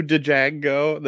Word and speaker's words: Django: I was just gonna say that what he says, "Fuck you, Django," that he shoots him, says the D is silent hillbilly.